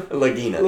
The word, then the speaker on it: Lagina: Yeah, guy's name Legina? His last name? – Lagina. – It's Lagina. – I pronounce it Lagina, too. Anyway.